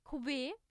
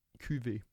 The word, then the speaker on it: Cuvée: den Cuvée. – Cuvée. Echt? Mhm. – Habe ich es immer falsch gesagt.